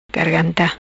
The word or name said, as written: Garganta